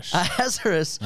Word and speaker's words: Ahasuerus: Ahasuerus.